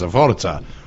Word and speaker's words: Forza: Forza, we say it like the Italians, Forza.